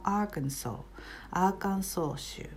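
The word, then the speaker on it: Arkansas: これは特殊な例でもあるんですけれども、アーカンソー州ですね。アメリカの南部にあるクリントン元大統領の出身地です。アーカンソー、最後の S は発音しません。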